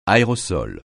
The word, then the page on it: aérosol: francais